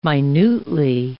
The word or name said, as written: minutely